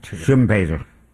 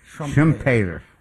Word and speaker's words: Schumpeter: Creative destruction comes from the Australian economist Joseph Schumpeter. – Schumpeter. – How do you say Schumpeter? – Trumpeter? – Schumpeter.